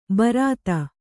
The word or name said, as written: ಭರಿತ